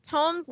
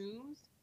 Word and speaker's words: tome: Are they tomes, tombs? Tomb would be double O.